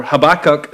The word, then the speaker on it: Habakkuk: a book called Habakkuk, or Habakkuk, as Americans say it.